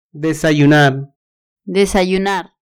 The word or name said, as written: desayunar